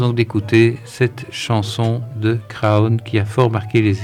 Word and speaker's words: Craon: c'est la chanson de Craon. Il faudrait prononcer Cron comme les gens du coin.